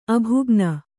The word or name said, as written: ಅಭಾಗಿನಿ